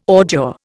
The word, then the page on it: ordure: Indian